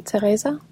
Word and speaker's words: Teresa: So in Danish, you would say Teresa.